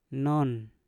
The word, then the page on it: non: Mpur